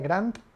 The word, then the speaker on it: Grant: Se escribe Grant con T al final, Cardone.